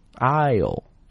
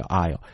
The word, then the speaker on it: aisle: aisle, 你看哪个字母没有发音呢？对，这个 S 是不发音的啊，叫 aisle。